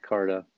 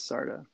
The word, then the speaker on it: Sarda: It looks like Carta. But it is Sarda. And if you've ever called me Carta, you're on my shit list.